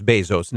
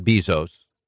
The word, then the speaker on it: Bezos: When it comes to Bezos, by the way, it's pronounced Bezos, not Bezos,